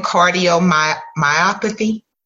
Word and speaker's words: cardiomyopathy: We're, we're definitely going to get into pronouncing it cardiomyopathy. Yes, cardio. I said it right.